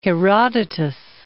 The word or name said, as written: Herodotus